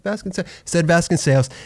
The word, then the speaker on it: Vasconcellos: It's funny because literally when we were talking, you know, before your episode, we were talking, I'm like, so how do you properly pronounce it? And you're like, Vasconcellos. I said, okay, Vasconcellos.